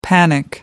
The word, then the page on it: panic: American English